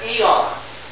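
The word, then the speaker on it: aeon: So, there was the One up above all this, and then emanating from the One were these six higher beings known as eons. A-E-O-N-S, but it's pronounced eon.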